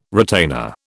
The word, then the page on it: retainer: British English